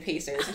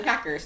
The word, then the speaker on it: Pacers: this girl pronounced Pacers, the basketball team. There is no K in Pacers. And she's like, yeah, the Packers.